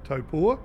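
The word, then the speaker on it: Taupō: Obviously, there's two circuits that you know really could work, and that's Hampton Downs, and you got the pronunciation right, Taupo.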